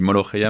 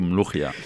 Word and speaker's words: molokhia: Égyptiens, on dit molokhia mais je sais que les Tunisiens le prononcent plutôt mlochia.